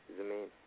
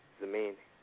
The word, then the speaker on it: Zamaine: Domaine. – What is it? – Domaine. – Vinay? – No, Domaine. – Say it one more time. I can't hear you. – Domaine. – Spell it for me, because I'm getting some static. – Z-A-M-A-I-N-E.